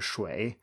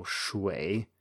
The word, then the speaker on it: shui: So, for example, uh, shui, as in water, shui, it's spelled UI, but it's pronounced as if it was written U E I. So shui.